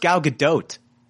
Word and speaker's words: Gal Gadot: It's pronounced Gal Gadot.